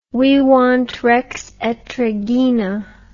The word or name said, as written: vivant rex et regina